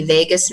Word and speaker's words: vagus: It's called the vagus nerve. It sounds like Las Vegas, but it's spelled V-A-G-U-S.